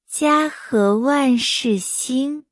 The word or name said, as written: 家和万事兴